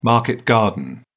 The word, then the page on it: market garden: British English